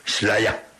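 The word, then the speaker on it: Slayer: Aquí, qué rollo, Mateu Ruiz, yo creí que iba a hablar de Junior, me dice no, ese es Laia, sería la pronunciación en inglés británico, para ellos la terminación er se pronuncia como a, Slaya.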